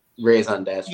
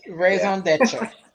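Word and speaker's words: raison d'être: Raison d'etre. – Yes, raison d'etre, yes.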